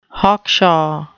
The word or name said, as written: hawkshaw